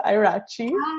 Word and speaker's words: Ieraci: So this is Melissa Ieraci.